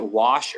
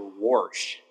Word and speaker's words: wash: I'll raise you one. That's like saying you can pronounce it wash or warsh. You ever heard someone call it warsh? There's no R in there.